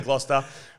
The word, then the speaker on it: Gloucester: I asked my New South Wales friend how to pronounce it. They said Gloucester.